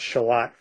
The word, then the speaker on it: Charlotte: One of the murders takes place in Shalott, spelled like Charlotte, North Carolina, but it's pronounced Shalott for reasons I'm not clear on.